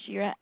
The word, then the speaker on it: Shira: Yeah, my name is Shira, S-H-I-R-A. I'm the enrollment S-H-I-R-A?